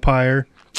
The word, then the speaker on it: pyre: Funeral pyre. – No. Yeah, okay. – It's pyre. – Yeah, okay. I know the come on, baby. Light my fire, but... – Yeah, it rhymes with fire.